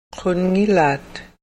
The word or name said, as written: qunngilaat